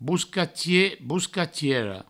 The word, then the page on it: boscatier, boscatiera: bouscatie, bouscatiero